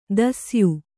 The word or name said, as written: ದಾಸೇಯ